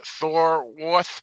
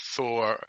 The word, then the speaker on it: Thorwarth: It's directed by Peter Thorwarth, or I would assume it would be pronounced Thorwath, because the W's are pronounced as V's in German.